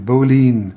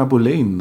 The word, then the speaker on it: Boleyn: B-O-L-E-Y-N, Boleyn. – Aus meinem Anne letzten Abenteuerfilm hieß die noch Anna Boleyn, ne? – Die wird in England verschieden ausgesprochen, okay. Das ist jetzt nicht irgendwie dahergeholt von mir, man kann sagen Boleyn oder Boleyn, da sind sich die Engländer selber manchmal nicht einig oder haben nicht eine Aussprache.